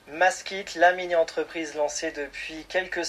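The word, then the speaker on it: Mask'it: N'hésitez pas à vous renseigner sur les réseaux sociaux Maskit. Ça s'écrit M-A-S-K apostrophe IT. Hein, c'est bien dit.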